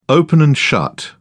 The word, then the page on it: open and shut: British English